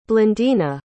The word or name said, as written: blandina